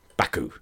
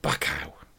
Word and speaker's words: Baku: Baku. – He texted me and he was complaining about my pronunciation. Why? I always fuck up my pronunciations. But text me saying, it's Baku, not Bacow.